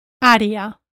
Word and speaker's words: área: For instance, the English word area in Spanish is área. Área.